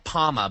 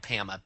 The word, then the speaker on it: Pama: Speaking of the booze, it is called it's called Pama, but it's spelled Pama, P-A-M-A.